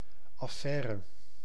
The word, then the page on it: chose: /ʃoz/